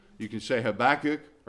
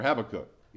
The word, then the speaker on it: Habakkuk: Or you can say Habakkuk or Habakkuk. Either way, either pronunciation is correct.